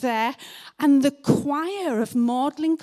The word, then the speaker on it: Magdalen: That's how you say it, Magdalen, not Magdalen. That's how it's spelled, but you say Magdalen.